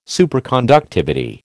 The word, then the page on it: Superconductivity: American English